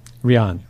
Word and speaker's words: Riane: Um, am I saying her name correctly? Is it Rain? – Riane. – Riane. Okay, Riane yeah. Eisler. Yeah. So interesting. I love her book so much, but I've been saying her name wrong.